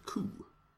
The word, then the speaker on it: Coup: Mitt favoritspel just nu heter Coup. Det stavas Coup men uttalas utan P.